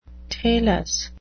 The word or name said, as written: talus